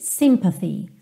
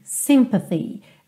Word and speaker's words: sympathy: Aqui também essa última palavra, normalmente sympathy, sympathy. A sílaba tônica é a primeira.